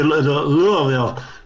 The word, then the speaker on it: Louisville: Louisville, yeah.